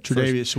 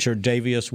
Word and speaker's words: Tre'Davious: and the pick before them was Tredav- how do I say it? – Tre'Davious White. – Tre'Davious White from LSU.